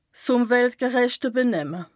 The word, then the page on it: ùmweltgerëchte Benemme: Bas Rhin